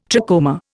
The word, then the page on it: trachoma: Indian